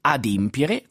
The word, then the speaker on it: adempiere: si può pronunciare in due modi, si può dire adempiere qualcosa oppure adempire qualcosa,